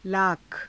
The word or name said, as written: laakha